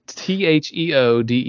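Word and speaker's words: Théoden: It's King Theodon. – This is not what I was. – Sorry, Theodon. – How do you spell that? – It's Theoden.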